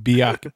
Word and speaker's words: Bialik: And this features, I can never say her name, but Dr. Mayim Blackie, who know her from Blossom. – Yeah, Blossom and Big Bang Theory. Mayim. – Oh, yeah, that's Bialik.